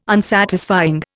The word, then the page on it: Unsatisfying: Indian